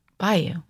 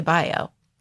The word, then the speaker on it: bayou: Bayou. Well, yeah, that's what I say. A lot of people say Bio.